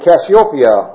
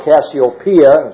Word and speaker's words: Cassiopeia: Andromeda is a woman, and Cassiopeia, or Cassiopeia, just pronounce it either way, I guess, also a woman.